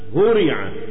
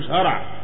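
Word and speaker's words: هُرِع: أشهرها طبعا فعل مشهور جدا هورع، هورع ما فيش هرع، هورع يهرعون، يو مش يهرعون يهرعون، فعل هورع ده فعل مبني المجهول.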